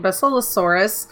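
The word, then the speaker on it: Basilosaurus: The Bacillosaurus. I think that's how it is. – You said it right the first time. – Awesome. I always think of the herb basil. – Mm-hmm. Basil. – Bacillosaurus, as they can stun all the manta that follow.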